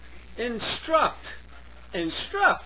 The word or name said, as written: instruct